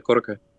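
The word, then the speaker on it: koroke (コロッケ): the potato koroke. Is that how you say it? – Yeah, that's how you say it, koroke.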